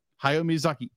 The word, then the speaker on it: Hayao Miyazaki: And it's so, I'd be like, I know other films have done this, but how Studio Ghibli, Hayao Miyazaki? – Yeah. – Hayao Miyazaki. Yes, I said it right.